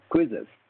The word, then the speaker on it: Quizizz: Another company is a company called called Quizzes. It is spelled as Q-U-I-Z-I-Z-Z, Quizzes.